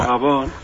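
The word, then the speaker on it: Gabon: Arrachaldeón, agachaldeón, ¿cómo es? ¿Cómo se dice? – Arrachaldeón o Gabón.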